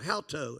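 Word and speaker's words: hauto (οὕτω): It's H-O-U-T, long O, hauto. That's how it's pronounced.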